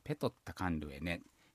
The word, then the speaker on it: ペオッタカンルエネ: ペオッタカンルエネ、ですね、普通はですね、ペオッタは、発音されるときはペトッタと、こういうふうに発音されますね。ペトッタカンルエネ、これで、川に私はいますよというアイヌ語になりました。